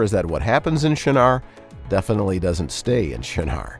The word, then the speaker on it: Shinar: shinar, which is a place.